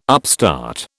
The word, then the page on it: upstart: British English